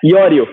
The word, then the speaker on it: Iorio: Seja muito bem-vindo ao podcast, Andrea Iorio. Falei certo? Falou certíssimo.